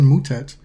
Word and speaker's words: Moutet: Speaking of pronunciations, he'll be playing Corentin Moutet, which is how Mr. Moutet pronounces it on the ATP website. – It is. He has anglicized his name.